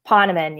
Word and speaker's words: Ponaman: Ponaman, yes.